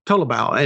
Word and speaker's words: Tolobao: The last part of his name, I looked it up earlier. Last part of his name is pronounced Bao, like the food, Tolobao.